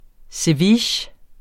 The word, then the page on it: ceviche: [ seˈviːɕ ]; [ seˈvitjə ]